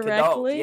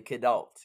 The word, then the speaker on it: kidult: Yeah, the kid adult. Yeah. A kid and adult collide, right? The kid adult. Yes. So K-I-D-U-L-T, the kid adult. Yes. – And how is it that we lose the kid adult, or what's the disconnect there?